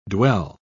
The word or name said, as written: dwell